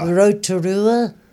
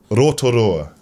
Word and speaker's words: Rotorua: Rotorua. – It's a lot like you've got to try and break down the words so you separate rotorua. It into like two, one syllable. Rotorua.